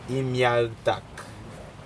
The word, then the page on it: imiactak: Aneityum